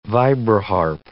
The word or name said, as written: vibraharp